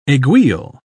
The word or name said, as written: aiguille